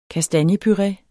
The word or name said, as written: kastanjepuré